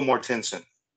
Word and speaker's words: Mortensen: All right, Viggo Mortensen.